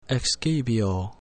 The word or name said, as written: excipio